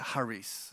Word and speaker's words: charis: It's actually pronounced charis, so we'll use the proper pronunciation.